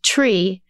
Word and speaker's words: Twi: Since they spoke Twi, pronounced tree, as their first language, I asked Atumfor to teach in Twi so they would understand.